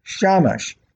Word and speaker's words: Shamash: However, by replacing the A vowel sound with an A sound, the same three Hebrew letters may now be pronounced Shamash, meaning servant.